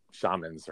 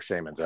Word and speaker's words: shaman: I don't want to be offensive to shamans or shamans everywhere. – Shaman. That's what Jim Morrison would say. It's shaman.